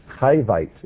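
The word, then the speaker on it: Hivite: Chi means life. Chivite, it's how we should pronounce it in English.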